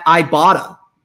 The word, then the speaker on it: Ibotta: But first, a quick word from our friends at Ibotta. See, I've been pronouncing it wrong the whole time, and there's a pronouncer right here on my copy that says Ibotta.